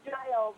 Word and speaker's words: Giles: Actually, it's Giles.